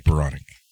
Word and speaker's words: Spiranek: We had Paige, I always want to say, is it Spironic or Spironek? – Paige Spironek. – Spironek.